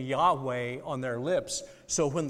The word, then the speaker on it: Yahweh: By the way, that is pronounced in Hebrew, Yahweh. That became in English because of translations, Jehovah. Some of our older Bibles have Jehovah.